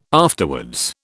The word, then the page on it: afterwards: British English